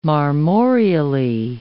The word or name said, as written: marmoreally